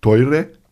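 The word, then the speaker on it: Teure (トイレ): Äh, Toilette heißt Teure, ja, so wie Toilet, nur ein bisschen japanisch ausgesprochen.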